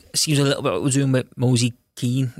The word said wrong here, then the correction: Moise Kean: if that's how you say his name. – What is it? – Kean, Ken is Ken. – Mosey Ken.